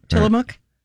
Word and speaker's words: Tillamook: Tillamook.